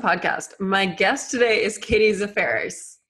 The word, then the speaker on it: Zaferes: And also, you got pretty close. Katie Zaferes.